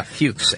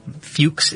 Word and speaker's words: Fux: Michael Fuchs. – Yeah, Fuchs. And it's spelled F-U-X. Fuchs is how you pronounce that. – Pronounce it Fuchs.